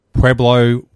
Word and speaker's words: Pueblo: Now, in high school, and I'll attempt to pronounce it, is it Pueblo Warriors? – Yes.